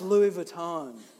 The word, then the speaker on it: Louis Vuitton: Louis Vuitton. All right, let's go. You know, here we go. And she's like, it's pronounced Louis Vuitton, genius.